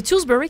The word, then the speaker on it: Tewksbury: J'entendais l'histoire en fin de semaine d'une jeune peintre, artiste peintre de 15 ans qui vient de la région de Stoneham, Tewksbury, dans le coin de Québec. Ouais. Là. – C'est Tewksbury qu'il faut dire, j'ai jamais su comment le prononcer.